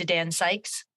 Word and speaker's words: Dan Sykes: Because today we are talking to Dan Sykes.